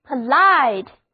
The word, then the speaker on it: polite: 首先来看这个单词 Polite， POLITE， O 字母发作 I 字母发 I 的本字母音，另外重音是放在第二个音节上的，单词就读作 Polite，意思是有礼貌的、文雅的。